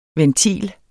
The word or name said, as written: VENTILEN